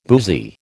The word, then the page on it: boozy: American English